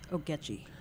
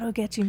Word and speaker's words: Ogechi: We're going to hear music from, I don't know how to pronounce it. – Ogechi. – Ogechi. – Tell us about Ogechi. – O-G-E-C-H-I.